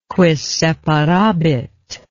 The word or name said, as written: quis separabit